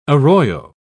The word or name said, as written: arroyo